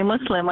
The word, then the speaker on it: Muslim: Not thinking that in, you know, English, when we have one S, we tend to pronounce it as a Z. And so if we have two S's, then we make sure it's an S. So that's the way it works. It makes us feel happier. So like you understand me. If you say Muslim, I say, oh, okay, he at least knows how to pronounce the name of the religion.